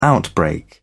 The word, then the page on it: outbreak: British English